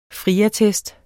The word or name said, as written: FRIATTEST